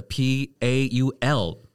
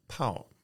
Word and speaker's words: Paul: 好，哎，OK，然后另外一位是来自德国的 Paul。 – Dage her. – 好，我一开始有一个错误的印象，不知道为什么，就是说我一直以为 Paul，当然那那那个 Paul 跟我的 Paul 是不一样的哦，哈，在德文里面，保罗这个 P A U L 的德文发音呢，就是 Paul。 – Yeah, for, for most people, it's Pao. Pao 面的 Pao.